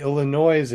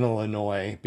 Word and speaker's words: Illinois: Why does Illinois have a S at the end if you're not supposed to pronounce it? – You know? I know. And when you hear people say Illinois, it just makes your skin crawl.